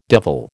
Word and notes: devil: American English

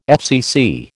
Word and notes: FCC: American English

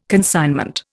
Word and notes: consignment: Indian

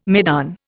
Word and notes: maidan: Indian

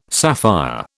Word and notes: sapphire: British English

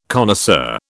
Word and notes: connoisseur: British English

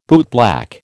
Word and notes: bootblack: American English

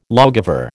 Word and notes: lawgiver: American English

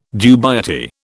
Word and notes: dubiety: British English

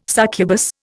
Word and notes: succubus: Indian

